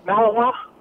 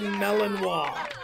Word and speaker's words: Malinois: Is it a Belgian Malinois? – That is correct, and kudos to you for the proper pronunciation as well. Give it up Thank for you again. It's the Belgian Malinois.